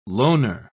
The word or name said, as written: loaner